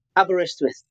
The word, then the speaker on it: Aberystwyth: It's Aberystwyth. How do you say that? – Aberystwyth.